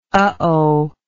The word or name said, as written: uhoh